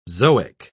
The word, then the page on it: zoic: British English